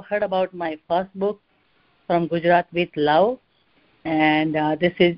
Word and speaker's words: Gujarat: Okay, so my state, the state that I come from is called Gujarat. – Gujarat, okay.